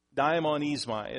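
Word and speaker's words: daimonizomai: Daimonismai is what the way it's pronounced.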